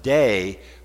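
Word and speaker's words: day (δεῖ): It's pronounced day, but it's a must.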